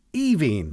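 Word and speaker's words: Aoibheann: The second one is pronounced Evine. – Mm. – And I think it's because the E-A-N-N somehow affects the first five letters.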